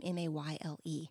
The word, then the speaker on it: Mayle: I read a book called A Year in Provence by Peter Mayle, I think is the way you say his name, Mayle.